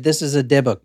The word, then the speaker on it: Dybbuk: The word Dybbuk, I think I'm saying it right still. I said it right the first time. – Dybbuk.